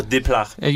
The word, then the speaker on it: Desplat: And not least because of Alexander, now how do you say that? Desplat? – Alexander Desplat. – You do the full French thing.